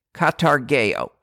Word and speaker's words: katargeo: The Greek word for destroyed is katargeo, spelled with a K, katargeo, which means to render powerless, or perhaps better, to deprive of power.